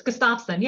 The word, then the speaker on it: Gustafson: Is it Gustafson? – You had it pretty good. It's Gustafson, yes.